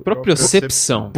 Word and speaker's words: propriocepção: Melhora a sua propriepção. Falei certo? – Propriocepção.